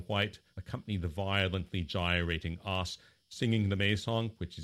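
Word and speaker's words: 'Oss: or in the local pronunciation, os.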